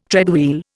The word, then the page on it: treadwheel: Indian